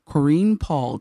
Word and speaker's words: Koreen Paul: To her left is Koreen Paul, did I say that correctly? – Correct.